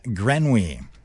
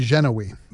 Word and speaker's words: Grenouille: That might be Genoui. – But the pronunciation says Grenouille.